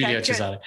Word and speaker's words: Giulio Cesare: Or just say Giulio Caesar in Italian. Yeah. Okay, Giulio Cesare. – Yeah. There we go.